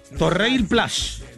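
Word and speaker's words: Torreilles-Plage: Torreir Plage. Así se pronuncia. En francés, obviamente, hay que afinarlo más para el francés, pero en español sería Torreir Plage.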